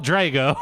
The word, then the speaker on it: Drogo: wake the dragon. – Or like, that's why she pours gold on his head. – Well, that's Khal Drogo. – You read the book. Pronounce it right. – Khal Drogo.